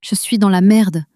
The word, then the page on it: je suis dans la merde: french